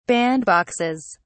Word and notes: bandboxes: British English

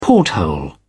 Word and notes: porthole: British English

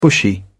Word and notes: bushy: British English

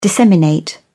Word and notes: disseminate: British English

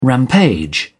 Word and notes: rampage: British English

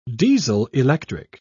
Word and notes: diesel-electric: British English

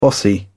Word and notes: bossy: British English

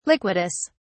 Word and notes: liquidus: British English

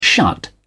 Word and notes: shut: British English

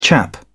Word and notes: chap: British English

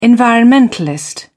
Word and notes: environmentalist: British English

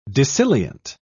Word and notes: dissilient: British English

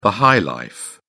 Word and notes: high-life: British English

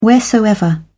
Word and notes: wheresoever: British English

